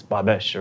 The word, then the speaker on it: Babos: She laughs at me when I say Babos. She's like, it's Babesh or whatever.